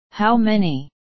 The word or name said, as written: how many